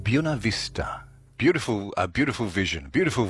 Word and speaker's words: Buena Vista: And it's funny you nailed the pronunciation of Buena Vista Farm.